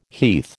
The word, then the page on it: heath: American English